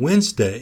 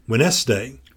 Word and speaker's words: Wednesday: Wednesday. Mm-hmm. She erroneously pronounced it Wednesday. – Wednesday. – Because that's how it's spelled.